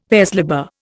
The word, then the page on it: vers libre: Indian